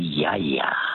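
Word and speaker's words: Yaya (γιαγιά): Well, in modern Greek, it's actually Yaya.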